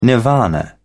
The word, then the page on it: nirvana: British English